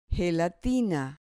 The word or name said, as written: gelatina